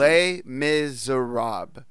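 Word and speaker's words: Les Misérables: It's Les Miserables. That's how you pronounce it.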